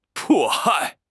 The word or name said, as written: 迫害